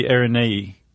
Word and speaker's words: Araneae: No, I think she said like it's pronounced like it looks. – Oh, okay. – So it would be Araneae.